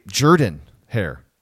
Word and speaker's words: Jordan-Hare: It's actually Jordan-Hare. It's pronounced that way. It's weird.